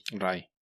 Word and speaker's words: Rai: Sí, es que debe ser más como haciendo énfasis en la A, ¿no? Rai. – Rai, sí.